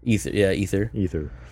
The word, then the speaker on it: Aether: Dubbed the Aether. – Ether. Yeah, the A is silent. Really silent in that one.